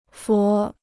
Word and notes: 佛: fú

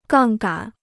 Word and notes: 杠杆: gàng gǎn

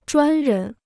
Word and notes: 专人: zhuān rén

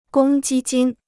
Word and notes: 公积金: gōng jī jīn

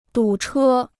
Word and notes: 堵车: dǔ chē

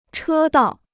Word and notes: 车道: chē dào

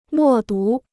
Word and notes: 默读: mò dú

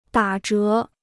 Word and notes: 打折: dǎ zhé

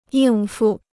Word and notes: 应付: yìng fu

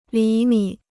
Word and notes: 厘米: lí mǐ

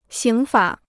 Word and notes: 刑法: xíng fǎ

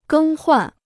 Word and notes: 更换: gēng huàn

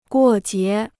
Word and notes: 过节: guò jié

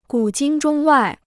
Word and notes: 古今中外: gǔ jīn zhōng wài

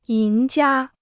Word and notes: 赢家: yíng jiā

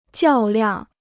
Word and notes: 较量: jiào liàng

